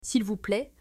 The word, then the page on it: S'il vous plaît: French